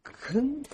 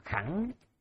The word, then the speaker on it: khứng: mình còn hay đọc là khẳng hoặc là khải,